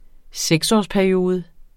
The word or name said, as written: seksårsperiode